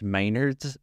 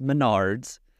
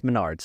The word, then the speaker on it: Menards: However, someone on our Instagram did confirm that it is in fact pronounced Menards. And it's a chain of stores local to South Dakota. So I feel slightly less embarrassed because the whole episode I'm like, Menards. But it is actually pronounced Menards, so.